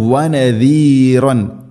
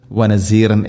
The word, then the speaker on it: نذيرا: പ്രത്യേകം ശ്രദ്ധിക്കേണ്ടത് വനദീറൻ ദാല്, അതിൻ്റെ ഉച്ചാരണം വനസീർ എന്ന്, വനസീറം ഇങ്ങനെ ഓതിപ്പോകരുത്. സാധാരണക്കാർ മീൻസ് തജ്വീദ് നിയമങ്ങളോ അക്ഷരങ്ങളോ ഉച്ചാരണമൊന്നും അറിയാത്ത ആളുകൾ പ്രത്യേകം ശ്രദ്ധിക്കുക, വനസീറൻ എന്ന് പറയരുത്.